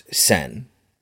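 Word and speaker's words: Sen (千): And the first symbol in the name Chihiro, if it stands on its own, it's pronounced Sen.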